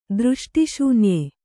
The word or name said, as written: ದೃಷ್ಟಿಶೂನ್ಯ